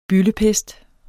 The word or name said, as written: byldepest